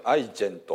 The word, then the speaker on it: iGen: 今日はですね、スマホの罪というですね、もう断罪してますけどね、スマホの罪と断罪してますけども、まあ、スマートフォンのお話でありまして、まあ、テキストは今日はこの、まあ、 11 章ですね、アイジェンと読みますけれども、 IGEN ですけれどもというところに、書いているお話、プラスアルファなんですけどもね、まあ、スマートフォンはもう私たちの生活に欠かせないものになりましたですね。